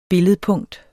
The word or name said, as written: billedpunkt